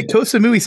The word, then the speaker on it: Koh Samui: So I go to the airport, look on the board, and I always pronounce Koh Samui wrong. Like, I think I said like Samoa, S-A-M-U-I. Oh. – I was going to say Koh Samui sounded right to me.